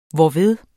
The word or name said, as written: hvorved